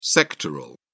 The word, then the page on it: sectoral: British English